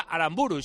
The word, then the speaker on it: Aramburu: Si no lleva tilde, será Aramburu, y si lo quiere decir de otra manera, pues tendrá que ir escrito de otra manera.